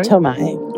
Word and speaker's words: Tornai: It's by Joseph Tornai. I believe I'm pronouncing that right. – Tornai. Yeah, it's originally written in Hungarian.